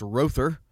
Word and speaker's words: Rother: I was over at Rother Brothers in Clinton, and that is pronounced Rother, R-O-T-H-E-R, Rother Brothers in Clinton.